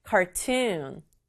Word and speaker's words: cartoon: cartoon, cartoon이에요. cartoon은 만화죠. 우리 친구들이 보는 그 만화를 cartoon이라고 하고요. 강조는 cartoon 뒷부분에 주면 되겠어요.